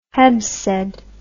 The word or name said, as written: Heb Sed